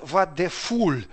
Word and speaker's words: Вадефуль: Но в немецком языке есть такое произношение, когда пх нужно произнести вместе. Вадефуль. И так непонятно, то ли пх, то ли х это сказал. В общем, пишется пх, произносится кто как.